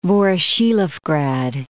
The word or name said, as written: Voroshilovgrad